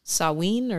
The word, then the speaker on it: Samhain: And the answer is, well, it's spelled S-A-H-W-I-N. So Samhain or Samhain? – Yep.